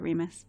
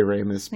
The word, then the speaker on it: Remus: His name is pronounced Remus, right? – I mean, I pronounce it Remus. – I say Ramis, but I don't think it really matters. – I don't think it really matters. Well, it's Romulus and Remus is the reference there.